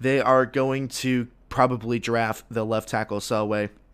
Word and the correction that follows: Penei Sewell: if I'm pronouncing Penei Sewell. That's how you pronounce it.